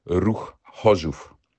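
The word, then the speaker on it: Ruch Chorzów: Die richtige Aussprache lautet Ruch Hoschuf.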